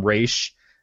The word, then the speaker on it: Ra's: It wasn't until they started calling him Ra's in the animated series of Batman in the 90s that anybody realized, oh, well, that must be how his name's pronounced.